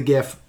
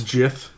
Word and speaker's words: Gith: Jith. – It is pronounced. – No, Gith. No, it is not pronounced Jith. – Yes, it is. – It is Gith.